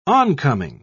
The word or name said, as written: oncoming